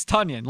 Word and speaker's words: Tonyan: Yeah, it's Tonyan, like Funyan Tonyan.